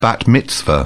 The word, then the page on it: bat mitzvah: British English